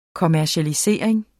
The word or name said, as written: kommercialisering